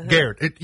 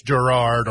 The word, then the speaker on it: Garrard: Well, of course, it's G-A-R-R-A-R-D, uh-huh. Garrett. You would want to say it's Girard or Garrard County. – Yeah. – It's Garrett County.